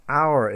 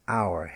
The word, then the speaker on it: hour: So the letter H is always silent in words like heir, the heir to the throne, H-E-I-R, silent H. Let's be honest, honest, he is an honest man, H-O-N-E-S-T. What hour is it, meaning what time is it, hour, H-O-U-R.